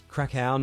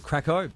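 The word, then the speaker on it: Kraków: And you've also said Krakow is Krakow, not Krakow.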